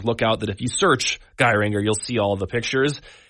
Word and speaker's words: Geiranger: It's tempting in the English to say it. Geiranger. That's kind of the Irish pronunciation. I think it's Geiranger.